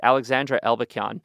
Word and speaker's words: Alexandra Elbakyan: I want to make sure I pronounce her name right, Alexandra Elbakyan.